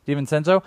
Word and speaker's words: DiVincenzo: Is that how you say his name? – That sounds DiVincenzo? – Right to me. – DiVincenzo.